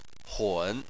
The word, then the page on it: hun3: chinese